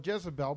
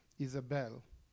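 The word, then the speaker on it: Isabel (איזבל): And I don't call her Jezebel because there's no J sound in Hebrew, and it's Isabel. That's the pronunciation in Hebrew for Jezebel.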